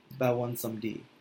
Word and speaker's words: Baron Samedi: It was Baron Samedi. And it's funny, I didn't learn until later from a Haitian coworker of mine that's how they say it in Haiti, Bawan Somdi.